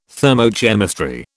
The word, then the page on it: thermochemistry: British English